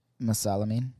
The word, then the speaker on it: Mesalamine: Mesalamine.